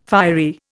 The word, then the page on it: fiery: Indian